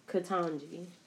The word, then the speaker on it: Ketanji: According to Wikipedia, which you can't always trust Wikipedia, but it's Ketanji. Okay, sounds right. Well, yeah. Like Dem- Jumanji.